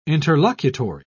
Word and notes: interlocutory: British English

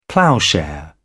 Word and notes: ploughshare: British English

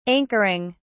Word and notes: anchoring: British English

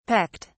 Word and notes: pecked: British English

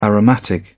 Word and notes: aromatic: British English

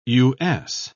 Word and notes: U.S.: British English

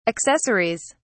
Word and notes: accessories: British English

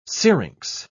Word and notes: syrinx: British English

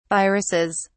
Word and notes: viruses: British English

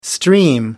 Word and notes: stream: American English

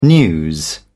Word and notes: news: British English

